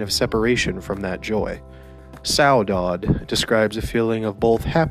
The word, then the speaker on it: saudade: The way you pronounce it is saudade. And it is spelled S-A-U-D-A-D-E, saudade.